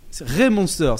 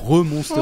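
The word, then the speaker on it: Re:Monster: Raymonster. – Raymonster, ça se prononce comme ça. – C'est R-E. Deux points, monster. – D'accord. – C'est un monstre en anglais. – Ça peut être re-monster.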